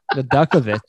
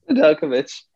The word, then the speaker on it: Nedeljkovic: Nedeljkovic. – Nedeljkovic. – Nedeljkovic.